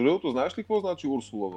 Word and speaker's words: Урсула: И това са ме поправили немско говорящи мои приятели, че ударението е на Урсула, не на Урсула. Урсула.